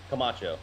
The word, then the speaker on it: Camacho: Camacho.